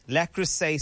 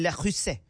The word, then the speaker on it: Le Creuset: You understand, I'm not even saying it right. They gave Le Creuset. That's what they did. Because if you understand the value of Le Creuset, you'd understand you shouldn't say it that way.